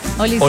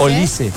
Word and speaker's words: Olise: Olise. – Olise. – Yo tengo con tilde en la I. – Con la I. Olise. – Acento en la I. Olise.